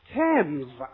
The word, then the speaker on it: Thames: Thames，就是 I Thames River，不过呢反正都约定俗成了。